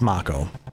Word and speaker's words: Mako: Well, the remake tells us it's Mako.